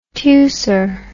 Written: teucer

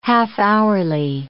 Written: half-hourly

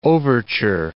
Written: overture

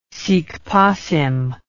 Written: sic passim

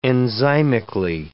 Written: enzymically

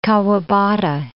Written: Kawabata

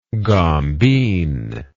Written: gombeen

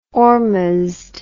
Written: ormuzd

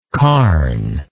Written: carn

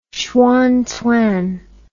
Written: xuanzang

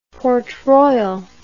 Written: Port Royal

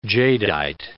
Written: jadeite

